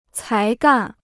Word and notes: 才干: cái gàn

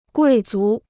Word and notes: 贵族: guì zú

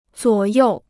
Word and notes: 左右: zuǒ yòu

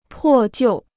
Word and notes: 破旧: pò jiù

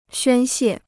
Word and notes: 宣泄: xuān xiè

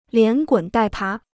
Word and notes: 连滚带爬: lián gǔn dài pá

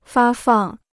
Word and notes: 发放: fā fàng

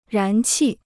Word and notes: 燃气: rán qì

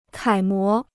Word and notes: 楷模: kǎi mó

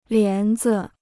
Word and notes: 帘子: lián zi

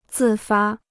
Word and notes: 自发: zì fā

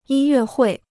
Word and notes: 音乐会: yīn yuè huì

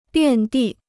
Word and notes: 遍地: biàn dì

